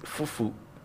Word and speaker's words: fufu: Because now you, the fufu started. yeah. – or fufu. How do you how, how do you pronounce it? – Fufu. Fufu. Fufu. fufu so whatever. you want to say.